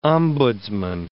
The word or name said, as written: ombudsmen